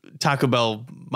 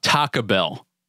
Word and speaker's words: Taco Bell: Taco Bell. – Taco Bell. – But you don't even, that second syllable of taco doesn't even get said almost. – Taco Bell.